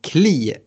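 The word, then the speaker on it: Klich: Bamford, ja, han fortsätter producera mål, men för mig faller fantasyvalet fortsatt ändå på mittfältaren och straffskytten Kli, som jag fick meddelat från en lyssnare att det ska uttalas då han är polack. Jag tycker Kli är bra, inte bara för att han är straffskytt, utan han ser riktigt fin ut i spelet också.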